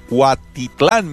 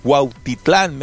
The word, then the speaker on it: Cuautitlán: Ya lo dije bien, no se va a burlar de mí. No lo dije mal. Cuautitlán, México. Lo que pasa es que se ríen de mí por allá porque no sé mencionar el náhuatl, que es el idioma original de los mexicas.